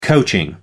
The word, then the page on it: coaching: American English